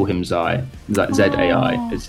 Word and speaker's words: Zai: And then we've even had, like, for Zai, we've had people say Zia, which is not the spelling, including family members who I won't name.